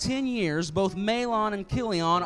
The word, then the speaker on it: Chilion: The man's name was Elimelech, his wife's name was Naomi, and the name of his 2 sons were Mahlon and Chilion. Some people pronounce that Chilion.